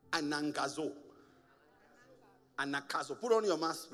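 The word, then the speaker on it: anagkazo: The real pronunciation of anakazo is anangazo. But because I'm the one preaching, say anakazo. We're going to keep it at anakazo.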